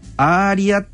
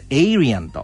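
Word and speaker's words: Aryan: 日本語の発音でですねアーリアっていうんじゃなくて英語発音だとエイリアンとで、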